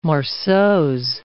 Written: morceaux

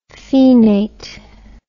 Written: phenate

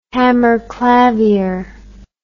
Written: hammerklavier

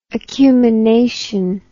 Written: acumination

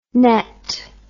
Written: nett

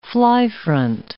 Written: fly-front

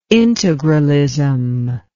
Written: integralism

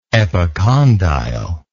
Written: epicondylar

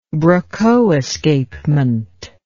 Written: brocot escapement

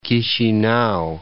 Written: Chisinau

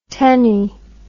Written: tenne

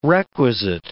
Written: requisite

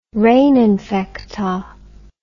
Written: re infecta

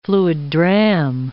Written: fluid dram

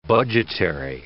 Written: budgetary

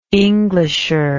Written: englisher

